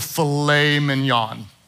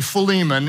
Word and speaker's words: Philemon: We're gonna study the book of filet mignon in the New Testament, which is not really filet mignon, it's really Philemon.